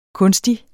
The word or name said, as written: kunstig